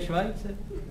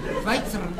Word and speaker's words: Schweitzer: No to se vyslovuje Švajce.